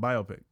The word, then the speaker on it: biopic: no, you said it right that time, biopic.